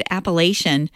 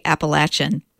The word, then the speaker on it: Appalachian: By the way, Aaron Copland pronounced that word Appalachian for about 30 or 40 years after he wrote that ballet until someone corrected him and said, well, it's actually Appalachian.